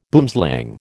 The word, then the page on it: boomslang: American English